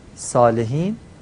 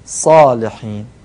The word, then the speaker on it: صالحین: صاد صالحین باید درشت و پر بگیم، صالحین، صالحین، دومی درسته.